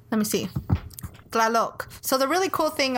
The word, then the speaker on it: Tlaloc: is when you see a word, you pronounce every letter. – Okay. – So it's T-L-A-L-O-C. – Tlaloc. – Tlaloc. Yeah, you're there.